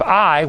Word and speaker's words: Ai: Now, when they came to the promised land at the city of Ai, which is AI, you know, we talk about AI today, but the words, the letters A and I are the I is how you pronounce it,